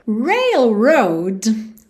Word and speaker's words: railroad: Porque a gente tem dois R's enrolados railroad. Então, o começo aí das duas sílabas, né? O começo de rail e o começo de road tem o R enrolado.